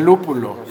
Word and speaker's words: lúpulo: Y lo que le decíamos hace ratito, lo que le da el amargor es el lúculo. – El lúculo. No, el, el, es el lúpulo. Saludos al pollo también. Saludos al pollo, este, no puede decir lúpulo. No puede pronunciar lúpulo, dice lúculo y pues muy callado.